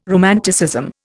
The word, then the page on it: romanticism: Indian